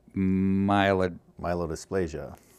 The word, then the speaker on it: myelodysplasia: myelodysplasia.